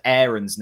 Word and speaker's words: Aarons: So Max Aarons, apparently we need to pronounce it Aarons now. He's changed the way he pronounces his name.